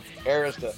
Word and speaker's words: Arista: Arista. Yeah. You had me pronouncing it wrong. Arista.